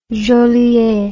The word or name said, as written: Jolliet